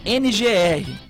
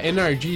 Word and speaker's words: NRG: A pronúncia certa seria NRG Stadium, porque NRG. A empresa que comprou o name right do estádio, ela é uma empresa de energia elétrica. Então, eles colocaram essa sigla Pra quando se pronunciasse Energy Stage, se tivesse a mesma coisa com Energy, de energia.